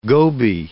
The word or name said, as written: goby